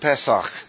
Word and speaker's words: Pesach: The word is P-E-S-A-C-H. And under C-H, I'll put a line. It's pronounced Pesach. The C-H is K sound.